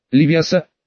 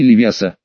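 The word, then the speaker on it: Левиоса: «Правильно нужно ставить ударение. Левиаса, а не Левиаса.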